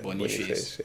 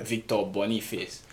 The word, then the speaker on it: Boniface: Yeah, Boniface. Yeah. Victor Boniface.